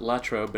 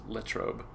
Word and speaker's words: Latrobe: Um, it's pronounced Latrobe. It's most of the country pronounces it Latrobe, but if you're from Latrobe, it's Latrobe. It's weird.